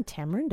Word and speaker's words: tamarin: Isn't a tamarind a fruit? – That's a tamarind. – Oh. – This is a tamarin, if I'm pronouncing it correctly. T-A-M-A-R-I-N.